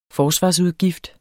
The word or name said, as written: forsvarsudgift